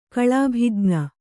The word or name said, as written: ಕಳಾಭಿಜ್ಞ